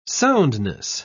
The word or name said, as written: soundness